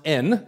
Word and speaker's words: en: It's actually pronounced en, as in our letter, which means that one has come to a place of security, a place of assurance, being anchored or immovable.